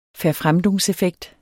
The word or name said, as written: verfremdungseffekt